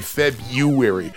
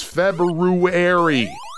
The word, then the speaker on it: February: Why does everybody say February? People, there is an R there. It is February.